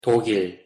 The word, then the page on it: 독일: Korean